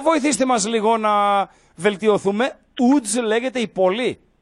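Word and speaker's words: Łódź: Κανονικά είναι λάμδα με γραμμή στη μέση, το οποίο προφέρεται U. Άρα η ομάδα λέγεται UDS. Όσο παράξενο και άσο ακούγεται. – UDS. Μισό Ούτς, λεπτό. Γιατί, η πόλη είναι Ούτζ.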